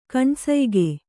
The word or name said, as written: ಕಣ್ಸಯ್ಗೆ